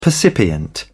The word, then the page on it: percipient: British English